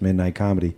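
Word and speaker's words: Midnite Comedy: That's Midnight Comedy.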